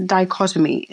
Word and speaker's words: dichotomy: But it's also, I never know how to say this word, but it's a dichotomy. Is that right, how you say it? – Yes.